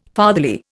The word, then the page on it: fatherly: Indian